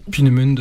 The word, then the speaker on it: Peenemünde: Peenemünde.